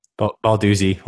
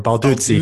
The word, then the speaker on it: Balduzzi: I'm Balduzzi or Balduzzi. – Balduzzi. Okay. Yeah. I was saying Balduzzi, like pizza.